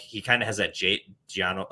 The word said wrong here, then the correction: Jonnu: – wow. I butchered that one. Jonnu Smith type.